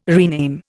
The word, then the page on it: rename: Indian